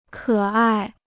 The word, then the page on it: 可爱: kě ài